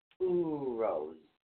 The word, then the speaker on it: Uruz: It's Uruz, and it's spelled U-R-U-Z, but it's pronounced O-O-O-R-O-O-Z, Uruz. That's Uruz.